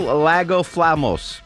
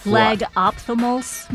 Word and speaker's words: lagophthalmos: Nocturnal Lagophthalmos. No, not even close. – Lagophthalmos, maybe? Ophthalmos. – Oh.